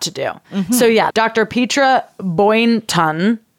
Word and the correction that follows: Boynton: Boynton. – That's how you say it. Boynton.